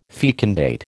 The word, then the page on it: fecundate: American English